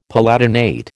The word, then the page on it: palatinate: American English